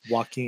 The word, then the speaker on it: Joaquin: Joaquin, whatever the hell his name is. – Joaquin.